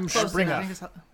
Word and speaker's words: Rumspringa: She gives Johanna and Thomas an update on their parents, and Thomas tells her that they're Amish and they're on Rumspringa. – Rumspringa. – That's how they pronounce it.